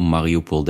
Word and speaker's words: Mariupol: Eigenlijk spreek je de naam Mariupol uit met een zachte L aan het eind. Dus je drukt je tong tegen je gehemelte. Le, le. Mariupol, dat je het weet.